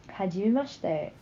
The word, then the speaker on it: Hajimemashite: How do you actually pronounce H-I-D- that? – Hajimemashite. Just like how I said it.